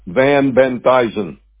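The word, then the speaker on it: Van Benthysen: Van Benthuizen.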